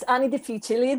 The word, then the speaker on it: Anni Difficili: It's Anni Difficili, that's how we pronounce the title.